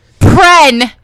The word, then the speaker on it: Prehn: Pren! P-R-E-H-N. Yeah. Not P-H... R-E-N.